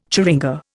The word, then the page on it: churinga: Indian